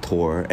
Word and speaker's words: Thor: I've heard his name pronounced different ways, but it's spelled like Thor, but it's like T-H-O-R, but I think it's pronounced Tor.